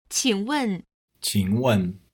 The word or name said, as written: qing wen